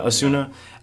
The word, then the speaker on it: Osuna: throwing these comments at female reporters about Acuña, am I saying that right? – Osuna.